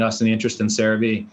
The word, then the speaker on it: CeraVe: CeraVe.